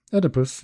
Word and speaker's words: Oedipus: How do you say in English? – Yeah, Oedipus, yeah.